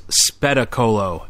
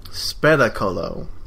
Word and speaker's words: Spettacolo: The previous film you talked about, Septicolo. It's pronounced Spettacolo, and that's why I couldn't find it on IMDb. – Spettacolo.